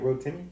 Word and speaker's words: Rotimi: Yeah, I do feel like Teyana Taylor and Rotimi's roles were kind of like, like, did you have to be? – Yeah, very short time. – How you say it? Rotimi. – It is.